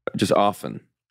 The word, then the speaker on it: often: Apparently, you're not supposed to pronounce the often. – T.